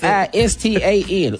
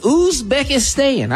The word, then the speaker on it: Uzbekistan: Uzbekistan. Uzbekistan.